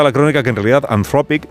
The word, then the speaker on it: Anthropic: El Mundo destaca la presencia junto al Papa ayer de Christopher Ola, que es el cofundador de la tecnológica Anthropic, me ha dicho Marta, se dice así, Anthropic, que está embarcada esta compañía en la poco disimulada tarea de ser vista como la IA buena.